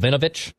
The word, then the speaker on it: Vinovich: There was a news that, is it Bill Vinovich? Is that how you pronounce it, Judd? The referee for the Vikings-Packers game? – Yep.